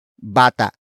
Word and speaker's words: bata: And that main topic is bata. Bata. Bata. Bata is spelled as B-A-T-A. Note the glottal stop at the end.